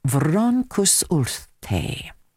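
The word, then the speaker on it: Froncysyllte: is Welsh and is pronounced Vroncus Urte. The choir, now in its 63rd year, has, well, a rather interesting history that I thought I'd pass on. Vroncus Urte,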